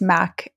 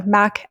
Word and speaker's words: Mack: I'm just Mac. My last name's not the easiest. So Mac, M-A-C-K, Bel Castro, but I'm sure it'll be linked up so people don't have to spell that Italian name.